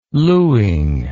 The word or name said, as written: luing